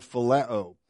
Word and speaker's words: phileo: A second Greek word for love, and it's the Greek word phileo. I'll spell that one for you, P-H-I-L-E-O, or you can spell it however you want. Just get the pronunciation, phileo.